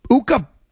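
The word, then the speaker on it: Ukko: No reason this Uka, which rhymes with Tuca.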